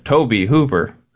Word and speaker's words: Tobe Hooper: I straight up, like, Googled how to pronounce his name, and it said Tobe Hooper. So, it's Tobe.